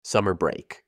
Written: summer break

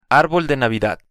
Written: arbol de Navidad

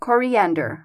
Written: coriander

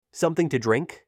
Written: Something to drink

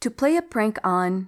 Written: to play a prank on

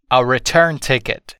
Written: a return ticket

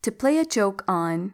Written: to play a joke on

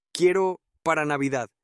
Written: quiero para navidad

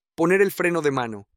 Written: poner el freno de mano

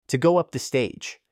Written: to go up the stage

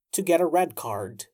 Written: to get a red card

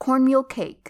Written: cornmeal cake

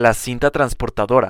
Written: la cinta transportadora

